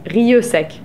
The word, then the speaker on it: Rieussec: How do you pronounce it? – Rieussec. – It's funny, my friends pronounce it Rieussec. – Ah. – I go by you.